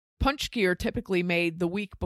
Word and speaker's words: pączki: This week on the podcast, we made pączki, which is spelled nothing like it's pronounced.